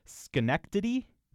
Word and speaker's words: Schenectady: I'm going to spell it. This is, again, in New York. S-C-H-E-N-E-C-T-A-D-Y. Let me see if I can wrap my mouth around this. Schenectady? – Well Correct. Done.